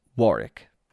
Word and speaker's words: Warwick: For the record, although spelled Warwick, it is pronounced Warwick.